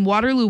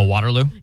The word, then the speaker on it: Waterloo: And then she said that you're saying Waterloo wrong. – Oh, Waterloo? – Yeah. – Because I actually pronounced the T. – Yeah, I think that's it. It's almost like Scranton.